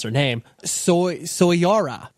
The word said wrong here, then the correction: Soraya: It's Soraya.